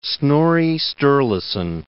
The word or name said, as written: Snorri Sturluson